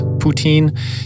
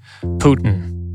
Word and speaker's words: Putin: For Westerners, it's a much more challenging name than one like Putin, which, by the way, I usually pronounce Putin, even though in the West uh, there's a very American way of saying it like Putin.